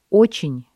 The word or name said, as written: очень